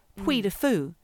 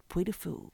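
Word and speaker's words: Puy du Fou: This is called Puy de Fou. It's called Puy de Fou.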